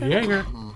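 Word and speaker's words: Joerger: That's how you say it? – Jaeger. – Jaegermeister.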